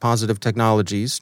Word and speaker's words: Positive Technologies: It's simply pronounced Positive Technologies, transliterated into Cyrillic, and then transliterated back into the Roman alphabet.